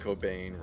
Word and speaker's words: Cobain: I just have to thank the universe that Kurt Cobain rhymes with brain.